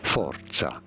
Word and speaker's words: Forza: This is a word from Italian, meaning force in Italian. For reference, in Italian, this is said as forza. Forza. – See, there's like a T in there. – You gotta roll your R's, apparently.